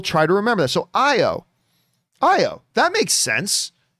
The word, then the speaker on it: Ayo: All right, Mary writes, AO is pronounced IO. Oh, see, I was talking about this. So, of course, the Dora Milaje second-in-command soldier, whose name is spelled A-Y-O, who, of course, appears at the end of Falcon and Winter Soldier in the last episode, I've always said I've never known how to pronounce it. I just pronounce it AO. But Mary is saying it's pronounced IO with no oo sound, short O, rather than I-I-O.